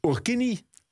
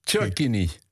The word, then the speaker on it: Cherkini: En nu moet ik een naam uitspreken die ik maar half kan uitspreken. Urkini? – Nee, Cherkini. – Cherkini.